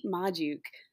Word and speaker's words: Magdziuk: It is Kate Magziuk.